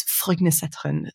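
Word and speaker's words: Frognerseteren: It's Frognesetren. So it's up in the hills. It's spelled sort of like Frogner. That's the place, Frogner, and then Setteren, Setteren.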